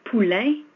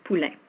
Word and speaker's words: Poulain: Il y a deux noms dans mon nom qui sont des sons rares en anglais. André et, le et Poulain, un, le un. André Poulain. Mon nom de famille, Poulain, est un homonyme du mot poulain qui s'écrit P-O-U-L-A-I-N, qui veut dire le bébé du cheval.